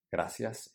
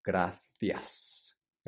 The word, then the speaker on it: gracias: Gracias is the way that we pronounce it in Latin America, gracias. In Spain, they say gracias. So the C in Spain is pronounced like a TH in English, like the, gracias, gracias.